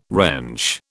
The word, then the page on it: wrench: British English